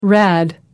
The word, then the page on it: rad: American English